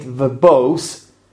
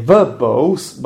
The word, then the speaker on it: verbose: V-E-R-B-O-S-E, verbose. Now, I'm pronouncing it verbose, and hopefully you have noticed that I stressed the second syllable. I didn't say verbose, but I said verbose.